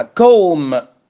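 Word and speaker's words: comb: خلوا كوم سي او ام بي يعني مشط ويمشط،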